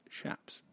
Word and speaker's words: chaps: The correct fun fact, the correct pronunciation of chaps is chaps.